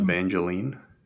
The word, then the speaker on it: Evangeline: Evangeline.